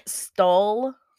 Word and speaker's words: Stull: Stull. I think is how you pronounce it. It's S-T-U-L-L. Stull. – Yeah, I think that's how it's pronounced.